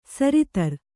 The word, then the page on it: ಸರಿತರ್: sari tar